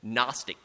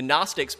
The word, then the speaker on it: Gnostic: So just like we have a silent K before our word knowledge, right? We have knowledge in English. Gnostic is GN, so the Greek word gnosis, which we hear as an N but has a G in front.